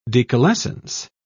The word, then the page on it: decalescence: British English